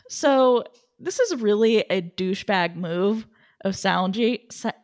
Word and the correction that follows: Saionji: I can't I don't know why I cannot say his name right. Saionji. There we go.